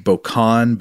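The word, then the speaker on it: bocannes: It refers to areas of fire-baked rock as, I think this word is French, so I think it would be pronounced Bocan, but it's B-O-C-A-N-N-E-S.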